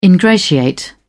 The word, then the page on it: ingratiate: British English